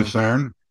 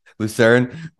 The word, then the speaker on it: Lucerne: Lucerne. – Lucerne,